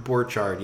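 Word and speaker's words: Borchardt: So to quote Mark Borchert from America, is it Mark Borchert? How do you say it? – Mark Borchard, yeah.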